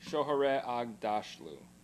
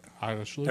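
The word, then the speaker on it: Shohreh Aghdashloo: Oh my gosh, there's a lot of Shohare Agdashlu. – Okay. – Agdashlu.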